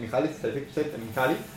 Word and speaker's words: Mihaly Csikszentmihalyi: Sí, que lo he dicho bien, Mihaly Csikszentmihalyi.